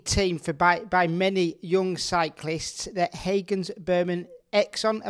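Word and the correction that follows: Axeon: am I pronouncing that one right, mate? – Hagens Berman Axion, but yeah, not bad, mate, not bad.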